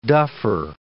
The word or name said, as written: duffer